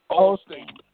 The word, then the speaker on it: Osteen: Osteen.